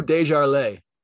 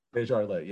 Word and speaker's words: Desjarlais: Maybe it is someone like Chasen Hines, or maybe it's, how do I pronounce his name, the CFL guard? – Drew Desjarlais. – Desjarlais, yeah. Maybe it's someone like him or someone else that they already have on the roster or something like that.